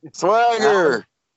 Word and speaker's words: Swagger: No, you didn't. You said Jack Swaggle. – Swagger? – There you go. – Swagger!